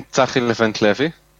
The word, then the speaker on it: Tsahi Levent-Levi: I'm Tsahi Levent-Levi.